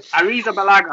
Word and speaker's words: Arrizabalaga: So, Arrizabalaga, I think, is how you pronounce it. – That is correct.